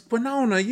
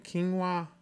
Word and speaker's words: quinoa: You know, them little things, the little grainy things, they look like, they always in every grocery store, this the hot item, it's quinona, you know. And I said, look, spell it, and I said, you mean quinoa?